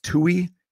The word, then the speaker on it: Tuohy: And then this family, I believe it's pronounced Tuohy, T-U-O-H-Y, family, Sean and Lee, or Lay.